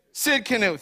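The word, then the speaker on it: Tsidkenu: Sid Canoe, thank you. See? Sid Canoe. Sit in the canoe.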